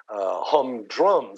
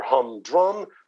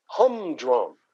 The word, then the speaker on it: humdrum: You don't say humdrum. Things are humdrum. Things are humdrum. The emphasis, of course, on the first syllable.